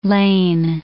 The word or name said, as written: Lehn